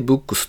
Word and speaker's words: VOOX: VOOX。これで Books と読むそうです。